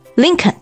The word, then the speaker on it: Lincoln: 所以林肯就读作 Lincoln 就可以。